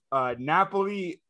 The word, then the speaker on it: Napoli: Is it Napoli? – Yes. – Okay. I got it. – Way to rub it in. I can't pronounce words and you can. Cool. – Napoli is the underdog at +270 and a tie is at +260.